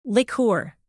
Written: liqueur

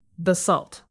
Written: basalt

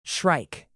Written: shrike

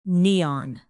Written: neon

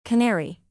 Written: canary

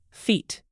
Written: feet

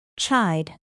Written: chide

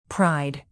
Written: pride group

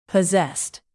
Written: posessed

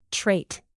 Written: trait

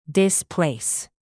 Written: displace

